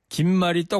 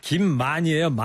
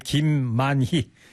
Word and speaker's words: 김만희: 그리고 김말이가 아니고요. 김만이에요. 만희. 만만자 기쁠희자 발음. 사람 사장님 이름이에요. 김만희.